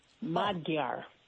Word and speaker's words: Magyar: So the Magyar, the G-Y is pronounced D-Y-E, like Gya. Magyar.